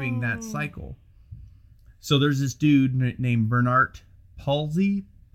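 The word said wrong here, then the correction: Palissy: Policy? How do you say that? – Policy.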